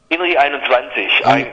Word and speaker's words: INRI 21: Oder 21, wie spricht man das aus? – INRI 21. Darf man ruhig 21 sagen.